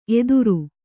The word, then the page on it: ಎತ್ತರ: Kannada